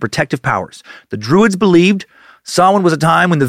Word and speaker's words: Samhain: Between October 31st and November 1st, spelled Samhain, pronounced Samhain. So that's cool. Thanks for making it easy, Celts.